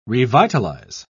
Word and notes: revitalise: British English

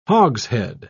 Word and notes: hogshead: British English

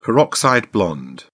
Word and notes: peroxide blonde: British English

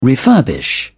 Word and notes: refurbish: British English

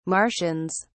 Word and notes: martians: British English

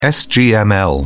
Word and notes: sgml: British English

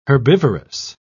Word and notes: herbivorous: British English

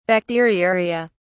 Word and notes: bacteriuria: British English

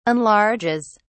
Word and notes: enlarges: British English

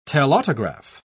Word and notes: telautograph: British English

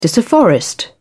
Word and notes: disafforest: British English

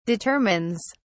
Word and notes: determines: British English

